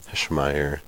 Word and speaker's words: Heschmeyer: We have our first guest, Mr. Joe Heschmeyer.